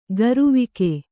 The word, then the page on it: ಗರುವಿಕೆ: garuvike